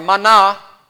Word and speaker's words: mana: And of course, the word manna, or mana, as it's pronounced in Hebrew, literally means, what is it?